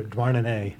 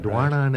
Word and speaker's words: Douarnenez: In Brest or Douarnenez. – Yeah, Douarnenez.